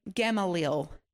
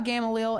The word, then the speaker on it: Gamaliel: And around 14 years old, his father sent him into Jerusalem to learn under a man named Gamaliel, G-A-M-A-L-I-E-L, Gamaliel. And you can actually find Gamaliel. Oh, no, I'm saying it wrong. Oh, I thought you... Gamaliel. We can see about Gamaliel in the book of Acts.